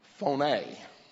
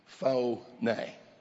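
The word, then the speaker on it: Phoné (φωνή): It's spelled P-H-O-N-A-Y. Phoné. Phoné. Let me help you a little bit. Have you ever heard of telephone? Phone is the Greek word phoné.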